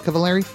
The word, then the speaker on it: Cavallari: Kristen Cavallari. Cavallari? Am I saying that right, Kevin? – Uh-huh. – Cavallari.